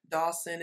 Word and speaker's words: Dawson: If you go to any digital platform and just plug in my name, it's Donita Dawson. It's spelled with an E, not an I. So it's D-O-N-E-T-A Dawson, D-A-W-S-O-N.